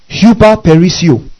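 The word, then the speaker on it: huperperisseuo: He uses the Greek word hyperperisio. Amen? Hyperperisio. Now I'll spell it for you. H-U-P-E-R, where you get the English word hyper.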